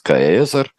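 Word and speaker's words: Caesar: Есть масса всевозможных предположений, вплоть до того, что вообще-то это тогда, а, судя по тому, что мы можем определить, произносился каэзер, а, и существовал и дифтонг двойной гласный такой вот, и а, не было различия, перед какими гласными произносится, произносится этот звук, всегда как «к».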